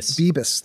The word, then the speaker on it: Bibas: Judge, I'm sure I'm butchering his name, Bibas? – Bibas, thank you.